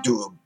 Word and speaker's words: Doubs: And like I said, in the region they call the Doubs, you know, I'm not sure if I'm pronouncing that correctly, D-O-U-B-S. – No, it sounds right to me.